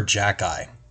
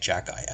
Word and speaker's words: Xhekaj: His name is Arber Jacki, and the way you spell his name is A-R-B-E-R. So far, so good. But the last name is spelled X-H-E-K-A-J, and somehow you get Jacki out of that.